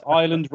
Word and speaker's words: Islay: It was Innis & Gunn uh, their Isla whiskey cask. Not, mm. – which is not pronounced Islay, as I found out to my oh, parents.